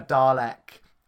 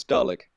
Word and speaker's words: Dalek: How do you pronounce it? – Dalek. No, I don't. Dalek. – Yeah, it's interesting. You pronounce the E. – I do. – Yeah, whereas we seem to pronounce it as an I.